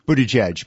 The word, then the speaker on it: Buttigieg: I think people are watching Paul Buttigieg, if I pronounce that correctly. – Buttigieg, but that's okay.